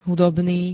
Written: hudobný

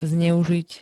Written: zneuzit

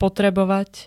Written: potrebovať

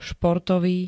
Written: športový

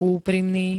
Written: úprimný

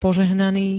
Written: požehnaný

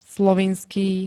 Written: slovinský

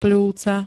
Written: pľúca

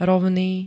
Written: ROVNÝ

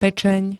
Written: pečeň

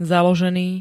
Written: založený

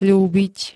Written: ľúbiť

